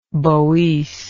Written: Boece

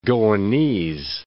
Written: Goanese

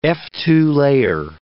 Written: Fa2a layer

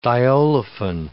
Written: diolefin

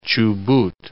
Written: Chubut

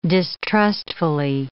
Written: distrustfully

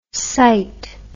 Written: cyte

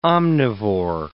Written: omnivore

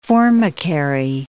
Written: formicary

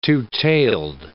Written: two-tailed

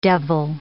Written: devil

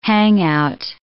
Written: hangout

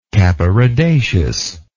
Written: capparidaceous

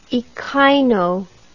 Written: echino

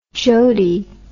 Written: jodie

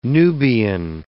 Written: Nubian

